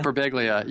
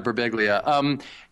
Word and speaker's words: Birbiglia: Like I read on your blog, you I don't know how to say his last name, Mike Birbiglia. – Mike Birbiglia, yeah, Birbiglia.